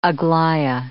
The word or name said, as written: Aglaia